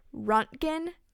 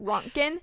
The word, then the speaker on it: Röntgen: Back in 1986, the unit that they used to measure radiation was the Röntgen. I totally said that wrong, but Röntgen.